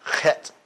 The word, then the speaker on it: Chet: There's really not an English equivalent because it's pronounced chet, chet. Everybody say that, chet. – It's back of the throat, guttural.